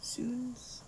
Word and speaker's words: Suuns: And I also just got a request for Suns or Soons. Do you know how to, do you know how to pronounce S-U-U-N-S? Suns? Soons? – Soons. – Soons, I think.